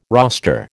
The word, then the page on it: roster: American English